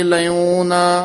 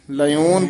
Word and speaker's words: लयोन: लयोन ये सबे गुण है थोड़ा जा भारा उच्चारण करना सबे गुण है बख्साए लयोन लयोन पाठ शुद्ध पाठ है मेरे लायोन छपिया हुआ सी